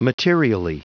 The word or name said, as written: materially